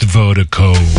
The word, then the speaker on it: Virdiko: And Vertigo is spelled V-I-R-D-I-K-O. That's V-I-R-D-I-K-O. Pronounced Vertigo.